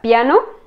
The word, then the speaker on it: piano: Porque, miren, aquí tienen la palabra piano, piano, pero no se pronuncia piano. Se pronuncia como si la I y la A estuvieran un poco como fusionadas, digamos, y se pronuncia piano, piano, piano. No es como piano, piano.